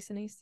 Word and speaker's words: Sinise: And I love that miniseries with what's his name that was on CSI, Gary Sinise. I don't know how to say his name. – Oh, Gary Sinise, yeah.